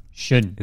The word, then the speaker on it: Xun: It's Shun. – Shun. – It's spelled X-U-N.